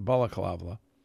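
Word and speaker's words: balaclava: Member of the Bundestag from the far-left party, Link, L-I-N-K-E, wearing a black Antifa baklava. How do you pronounce that? Balaklava. A balaklava.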